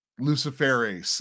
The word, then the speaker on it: Luciferase: the Luciferis in it. No, but we're just crazy people. – Lucifer Ace. – Luciferase, that's right. – There was an emphasis on the ace there.